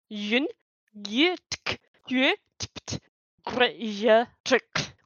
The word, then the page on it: Jin-Gitaxias, Progress Tyrant: Phyrexian